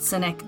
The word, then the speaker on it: Sinek: It's ironic that his name is pronounced Cynic when he is anything but cynical.